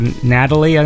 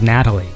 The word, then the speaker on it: Natali: His name's uh, Vincenzo Natalie, I think. It's spelled, I always thought it was Natali, but I think he pronounced it Natalie.